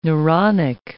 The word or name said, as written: neuronic